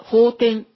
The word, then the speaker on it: 法典: 法律の方に経典の典で法典って読むんだそうです。